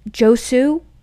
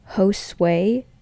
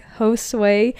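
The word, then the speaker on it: Josué: I'm like, "How would you pronounce this name?" And she goes, "Josue." I'm like, "You've got to be kidding me."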